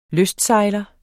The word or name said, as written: lystsejler